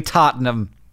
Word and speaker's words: Tottenham: Two goals to push Liverpool into the finals of the Champion Leagues against Ajax or possibly Tottenham.